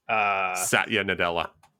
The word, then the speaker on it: Satya Nadella: So this has been coming up a lot recently because of some comments from, how do you say the name of the CEO of Microsoft? – Satya Nadella. – Thank you.